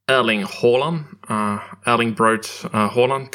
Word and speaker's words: Erling Braut Haaland: Which then Erling Braut Haaland converted.